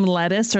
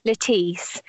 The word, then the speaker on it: Lettice: Do you pronounce her name Lettuce or Lettice? – lettice It's Lettice.